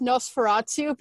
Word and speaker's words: Nosferatu: I thought you were going to pronounce it, cuz it's technically pronounced Nosferatu,